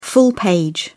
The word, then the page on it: full-page: British English